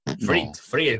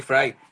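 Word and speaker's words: Fried: Friet, no. como se pronuncia. Sí, Fry. Oh, Sí, sí.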